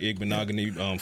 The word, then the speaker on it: Igbinoghene: We'll see what happens with Igbinoghene. Former first round pick, a lot of pressure on him, and he looks ready for his quote unquote fresh start. – That pronunciation. – Yeah, I got that down.